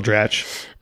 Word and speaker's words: Dratch: It stars Amy Poehler as well as, I always get her last name wrong, Rachel Dratch? – Yeah, Rachel Dratch.